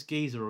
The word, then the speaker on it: Geezer: Oh, what, Geezer Butler? – That's it. I never know if it's Geezer or Grazer, but yeah.